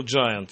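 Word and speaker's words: Giant: А второе слово «джайант» — это великан, гигант, пишется буква «G» первая, как «Джордж», потом «I-N», «I-A-N-T», да. – «Гиант», «гиант».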